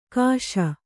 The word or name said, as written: ಘೋಷಿ